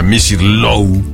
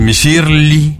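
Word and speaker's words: Misirlou: De hecho, Misir Lou, en griego así es como se pronuncia Misir Lou. En turco es Misirli, en egipcio y en árabe es el Miser, que es una canción que aparece en aquella película de Pulp Fiction.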